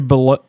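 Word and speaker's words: Beaulieu: So after they recorded Ember to Inferno, that's when Corey Bolu. I'm guessing that's how it's pronounced. – Yeah, because he's from Jungle Book. – Yeah, Baloo the bear.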